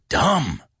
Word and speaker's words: dumb: Remember that B we don't pronounce? It's a silent B. You're dumb.